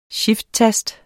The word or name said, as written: shifttast